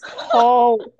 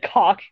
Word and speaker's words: caulk: Caulk. It's an aw sound. – I said caulk gun. – You said cock gun.